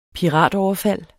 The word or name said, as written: piratoverfald